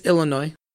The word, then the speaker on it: Illinois: It's Illinois. There, the S is silent.